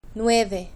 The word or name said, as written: nueve